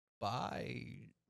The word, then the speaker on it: baijiu: So what I know about baijiu is that it's pronounced baijiu. – Yeah, that's very good.